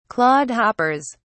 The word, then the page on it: clodhoppers: British English